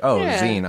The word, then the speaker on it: zine: Because when you look at Zine, unfortunately, yeah, that is how you're supposed to pronounce it. But when I see that though, I think magazines. I was like, zine, obviously.